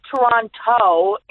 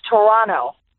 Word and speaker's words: Toronto: First of all, I learned it's not Toronto, it's Toronto. The second T is silent.